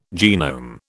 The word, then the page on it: genome: British English